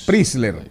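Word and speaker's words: Preysler: Isabel Preisler o Prisler, qué sé yo cómo se pronuncia. Prisler.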